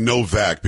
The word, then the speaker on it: Nowak: This Henry, and again, it looks like Nowak, but we were saying Novak because I think that's how they pronounce it there.